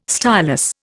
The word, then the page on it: stylus: Indian